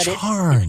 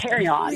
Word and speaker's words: carrion: But charn. – It's carry on. – Really? C-A-R-R-I-O-N? Is that how you spell it? – I think so.